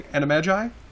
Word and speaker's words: Animagi: but it's just some interesting facts about let's pronounce this word correctly. Animagi? Am I pronouncing that right? – Yeah.